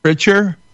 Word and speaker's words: Pitcher: Molly Pitcher? – Pitcher, like baseball pitcher.